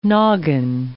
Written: noggin

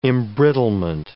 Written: embrittlement

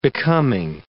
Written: becoming

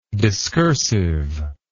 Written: discursiveness

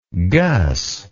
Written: gus

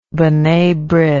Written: bnai brith

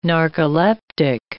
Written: narcoleptic

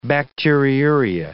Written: bacteriuria

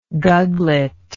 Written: guglet

